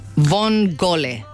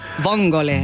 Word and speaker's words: vongole: Vongole. Así se pronuncia almeja en italiano. Vongole. – Vongole.